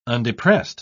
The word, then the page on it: undepressed: British English